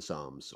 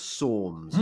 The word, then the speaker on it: Psalms: Yeah, I had a Calvinist on the other day, and his church was well into the Psalms, or the Psalms, as he called them. That's some sort of American pronunciation.